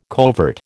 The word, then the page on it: covert: American English